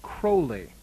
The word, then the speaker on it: Crowley: It should be noted that his family name, even before he was born, was pronounced Crowley. Some people remember this better by thinking that his name rhymes with the word holy.